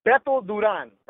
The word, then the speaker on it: Duran: It's Beto Duran, right? That's how you say it. But I know that people can't say Beto, so they say Beto.